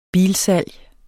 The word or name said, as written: bilsalg